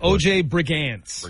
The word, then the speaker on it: Brigance: And how do you pronounce this guy's last name? – O.J. Brigance.